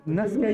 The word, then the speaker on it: Nazca: et je suis avec euh, Camille de Nasca Édition. – Je me trompe pas dans la prononciation. C'est exactement ça.